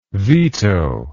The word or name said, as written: vito